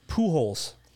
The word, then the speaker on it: Pujols: So Albert Pujols, which is still the funniest name ever. Do you know who that is? – So no. – Good. – Wait, what's his last name? – Pujols.